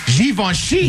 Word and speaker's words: Givenchy: You're supposed to pronounce it with a Z, according to this. It's Z-H-E-E-V-O-N-S-H-E-E. Givenchy.